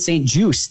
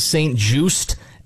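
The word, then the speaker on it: St. Juste: I just found out, it's Benjamin St. Juice. The T is silent. I did not know that.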